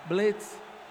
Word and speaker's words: blitz: Então já chegou já na blitz, ali no primeiro ataque, blitz, no blitz, é como se pronuncia a palavra, blitz, é como se pronuncia.